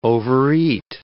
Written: overeat

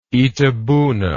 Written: Itabuna

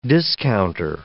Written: discounter

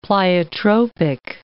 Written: pleiotropic

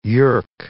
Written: yerk

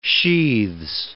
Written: sheaths

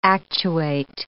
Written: actuate

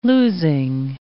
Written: losing

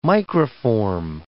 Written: microform

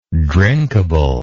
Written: drinkably

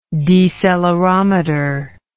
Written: decelerometer

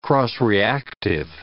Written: cross-reactive

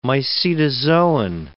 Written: mycetozoan

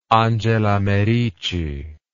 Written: Angela Merici

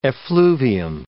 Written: effluvium

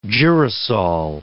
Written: girasol

